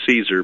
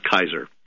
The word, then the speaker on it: Caesar: We say Caesar because we don't know how to pronounce Latin, but I took Latin in high school, college, and in the church, so trust me, it's Kaiser.